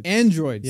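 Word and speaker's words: Annedroids: You starred in the Amazon original series Anadroids. – Androids. Yeah. – But it's spelled with an A-N-N-E, Androids.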